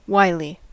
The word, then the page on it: wily: American English